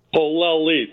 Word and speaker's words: Polelli: Polelli, three syllables, Rick.